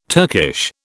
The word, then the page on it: Turkish: British English